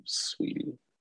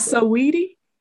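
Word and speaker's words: Saweetie: I just found out how to say her name, Sweetie. – It's not so weedy? – It's not, apparently.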